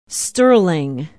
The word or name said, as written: sterling